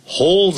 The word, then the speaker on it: hold: Dear, hold something dear. Hold is H-O-L-D. 不要念成 hold，那个 H-O-L 那个那个尾音呢，念成欧洲的 O 啊，把它念出来 hold，舌尖再抵上去。 Hold your love dear，就是珍惜 D-E-E D-E-A-R.